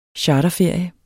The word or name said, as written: charterferie